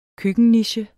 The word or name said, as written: køkkenniche